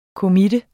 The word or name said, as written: committe